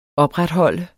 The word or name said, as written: opretholde